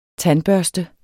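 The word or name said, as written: TANDBØRSTEN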